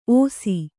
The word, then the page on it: ಒಸಿ: Kannada